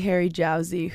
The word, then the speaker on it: Harry Jowsey: I am with the Harry Jowsey who we just... I love how you laugh when I just say it. Did I say it wrong? – No, that's perfect.